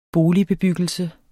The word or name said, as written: boligbebyggelse